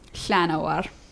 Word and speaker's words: Llanowar: I'm still mad about Llanowar elves. – Elves, because I believe you should pronounce the double L as the proper Welsh pronunciation. – Mm. Yeah. – Llanowar.